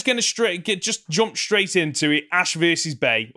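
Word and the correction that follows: Bea: or B as it's pronounced.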